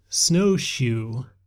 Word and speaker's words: SnowXu: I speak Chinese, so there was one on Kickstarter that caught my eye because it used the letters XU, which would be pronounced shu in Chinese. And so this one is called Snowshoe, S-N-O-W-X-U.